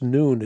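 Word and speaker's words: noon (نون): It's pronounced noon.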